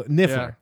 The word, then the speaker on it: Niffler: The Nerf, is it the Nerfler? Is that how you say it? – Yeah. The one who was the thief? – Yeah, the Niffler.